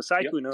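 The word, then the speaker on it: Sykkuno: but Sykuno is how you say it, right? Sykuno? – Yeah. – Okay, so Sykuno.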